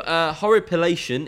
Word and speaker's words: horripilation: Horripilation is the medical term for which bodily sensation? – You pronounce that like a fucking champion.